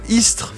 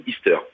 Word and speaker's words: ISTR: dis moi est-ce que je le prononce bien ce mot breton, Istres Istres, ouais, exactement, voilà. Alors. Euh, on peut on peut le prononcer. Euh, enfin d'ailleurs, beaucoup de gens le prononcent de deux manières différentes. Euh, sinon, c'est ISTR ou Easter.